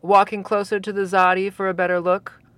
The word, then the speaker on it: Xsati: It's spelled X-S-A-T-I. And it's pronounced with a Z. Zati."